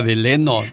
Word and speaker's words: Delano: Dice, saludos para la cuadrilla de Fidel que andamos trabajando en Deleno, California pero dice Delano. – De, es que ah. así se escribe, pero se, pronuncia Deleno.